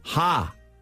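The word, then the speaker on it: ha (ห้า): And the reason is in that in Thai, you pronounce the number 5, ha.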